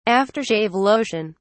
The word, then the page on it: after shave lotion: British English